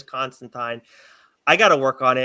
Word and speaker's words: Constantine: is it Constantine or Constantine? – Mm. – Constantine rhymes with swine.